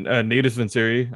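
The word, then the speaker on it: Natus Vincere: Yeah, Natus Vinciri.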